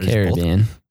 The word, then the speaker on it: Caribbean: Before Pirates of the Caribbean came out, I always called it Caribbean. Is there a correct or is it Caribbean? – Caribbean. Nice. I've been saying it right the whole time.